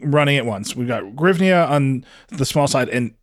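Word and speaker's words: hryvnia: In English, I think it's Grivnia. Grivnia is good. Yeah, Grivnia is very good.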